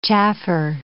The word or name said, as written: chaffer